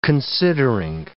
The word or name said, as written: considering